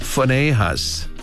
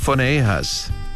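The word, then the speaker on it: Phinehas: 이렇게 발음합니다. 퍼네이하스. PH로 발음을 해요. 퍼네이하스. 이렇게 발음하시고 그 다음 엘르아살은 엘리에이저.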